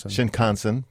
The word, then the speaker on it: Shinkansen: I have been on the Shinkansen. I think I'm saying that right. – Shinkansen. – See, I was on it, but I didn't know how to pronounce it.